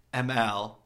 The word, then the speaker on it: Emile: Then the next bit is, I was saying Emile's name wrong yesterday, that it's actually pronounced ML, and I'm so sorry. It's her friends that got in contact with me and were like, just think of like the letter M and the letter L, ML, way better than Emile.